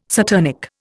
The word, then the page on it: saturnic: Indian